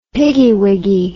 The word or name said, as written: piggywiggy